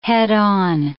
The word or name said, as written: head-on